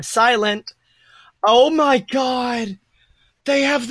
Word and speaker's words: Psilent: It's spelled P-S-I-L-E-N-T because the P is silent. – Oh, I would have just said P-silent. – No, the silent with a P, silent P.